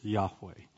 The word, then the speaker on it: Yahweh: And most scholars believe that that is pronounced as Yahweh.